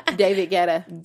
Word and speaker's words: Guetta: David Guetta.